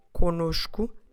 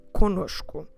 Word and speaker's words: conosco: So notice that the first syllable is again nasal, com, and then the second syllable, because it has a different N, will actually have the sound of nosh. So com nosh. And then we finish with cu, conosco, stressing the middle one, nosh.